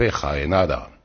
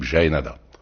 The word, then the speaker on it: Jaenada: Par exemple, tenez, votre camarade Philippe, Felipe, on l'appellerait en Espagne Felipe Jaenada. ici on l'appelle Philippe Jaénada.